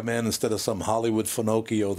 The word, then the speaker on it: Finocchio: It's Finocchio. That's how you pronounce it.